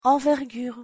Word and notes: envergure: French

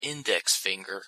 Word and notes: index finger: English

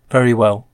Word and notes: very well: English

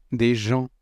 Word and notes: des gens: French

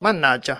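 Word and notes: mannaggia: Italian

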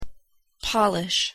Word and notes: polish: English; noun; verb

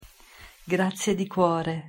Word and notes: grazie di cuore: Italian

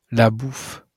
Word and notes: la bouffe: French

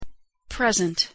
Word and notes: present: English; noun; adjective

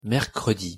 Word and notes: mercredi: French

